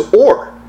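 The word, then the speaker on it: or (עור): But leather means spelled ayin vav resh, which you pronounce as or.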